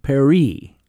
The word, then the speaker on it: Paris: Paris. Excuse me, Lights of Paris, to rhyme with Cherie.